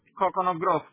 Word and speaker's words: Coconut Grove: Coral Gables y Coconut Grove, que literalmente Coconut Grove está justo pegado ahí. – Coconut Creek, ¿me sale? – Coconut Grove. Grove, se escribe Grove. Coconut Grove.